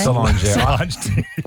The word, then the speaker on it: Solange: Remember she wrote a whole album after that big fight in the elevator with her sister Solange? Is that how you say her name? – Solange.